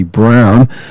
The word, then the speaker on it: Braun: It was B-R-A-U-N. I always pronounce it Braun, but someone told me that it's really Ari Brown.